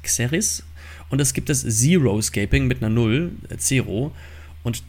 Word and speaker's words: Xeriscaping: Und zwar gibt es das sogenannte, im Englischen spricht man Zero-Shaping aus, das habe ich ja eben, Zero-Scaping.